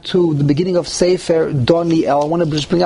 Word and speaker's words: Doniel: Okay, Doniel. The emphasis is on the Yud. – And the is under the Yud.